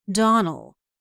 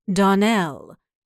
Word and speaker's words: Donnell: "Clarice Almira told me that you pronounced our name Donnell. Now, Miss Shirley, the correct pronunciation of our name is Donnell. Accent on the last syllable.